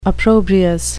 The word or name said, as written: opprobrious